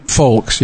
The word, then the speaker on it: Fowlkes: It's Folks, right? – Folks, yes. – Yeah, F-O-W-L-K-E-S. – That's correct.